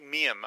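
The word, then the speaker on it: Miehm: It's drawn by Grant Mime? How do you say that? – I think Miam, I thought. Miam? I thought okay. – That's the way they said it on Secret Origins.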